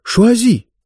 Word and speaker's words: шуази: — Ну да, он сказал «шуази», причем ударение на французский манер в конце.